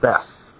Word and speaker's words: Beit: It's a Beit. In English Bibles, they pronounce it Beth.